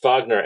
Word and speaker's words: Wagner: Is it Wagner? – It's Wagner.